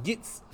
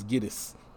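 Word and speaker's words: Gittes: Yeah, and also Noah Cross's John Huston's mispronouncing of the name what's up, Mr. Gitz? Yeah, it's Gittis.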